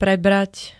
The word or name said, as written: prebrat